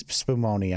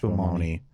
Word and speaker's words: Spumoni: No, it's Spumoni. I'm sorry, it's pronounced Spumoni.